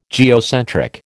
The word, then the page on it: geocentric: American English